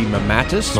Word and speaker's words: mammatus: Yes, uh, the pronunciation would be mammatus.